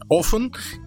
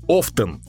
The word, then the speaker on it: often: Эм, по-русски оно звучит как часто, а по-английски есть два варианта его произношения. Often и often.